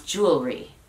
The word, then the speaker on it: jewelry: Por ejemplo, si le preguntas a alguien detenidamente que te diga cómo pronunciar jewelry, a lo mejor te va a decir jewelry, jewelry. Pero ya las carreras te lo dicen más rápido. Somos más flojos y queremos resumir todo en menos sílabas.